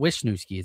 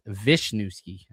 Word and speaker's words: Wisniewski: It's not Wisniewski. It's Wisniewski.